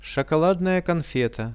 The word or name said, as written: shokoladnaja konfeta